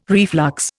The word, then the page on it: reflux: Indian